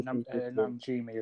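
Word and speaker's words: Najimy: I don't think they've confirmed that yet, but we've got Bette Midler returning, Sarah Jessica Parker, and Kathy, and I cannot pronounce her last name, it's Najimi. Is that correct? – I think you're right. Najimi is, yes. I think you're right on that.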